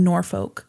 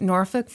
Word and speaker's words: Norfolk: Okay, there's Norfolk, Virginia. The L is silent.